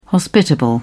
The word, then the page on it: hospitable: British English